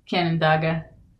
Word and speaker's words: Canandaigua: Canandaigua, New York? – Canandaigua? – Is that how you pronounce it? Guys, English is my second language. – Yeah, fair. I think it's Canandaigua.